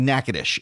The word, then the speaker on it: Natchitoches: If you ever see the name Natchitoches, you won't know it's pronounced Natchitoches. That's how you can tell a native of Louisiana from someone who's not a native of Louisiana. If you're not a native of Louisiana, it's Natchitoches, but it's actually Natchitoches, and it's Natchitoches meat pies.